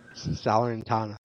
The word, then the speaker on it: Salernitana: They might not be bottom of the table anymore, but Salentina. Is that how you say it? I always say it wrong. – Salernitana. I'm gonna get it one day. – Salernitana. Thank you.